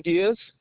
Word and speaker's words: Diaz: Steven Diaz, D-I-A-Z.